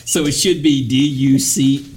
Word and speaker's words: Duquesne: Duqueskine? – Right there. – Which one? – Yep. – Here? – Yep. How do you pronounce that? – Duquesne. Yep.